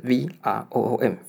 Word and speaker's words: Vroom: 那 Vroom 呢怎么念？就是 V R O O M V 加一个房间的 room，OK 叫 Vroom。